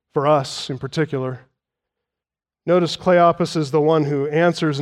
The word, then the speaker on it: Cleopas: This is Cleopas, short O sound, omicron used there, shortened form of Cleopatras, meaning illustrious father, glorious father.